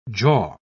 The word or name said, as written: jaw